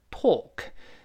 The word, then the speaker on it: talk: 当然，这个 talk 又是美国呃英语的特色，英式发音读成 talk，talk for o 就是半圆形加两个点儿这个长音 talk。但美音中呢，我们说这个半圆形加两个点的这个 o，它变成叹气音啊，talk talk，确实这嘴更舒服了，不用撅嘴了。